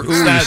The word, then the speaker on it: Uwe: It says pronounced with a long U. – Get a name change. – Rhymes with gooey. – Yeah, gooey. – Dr. Uwe says...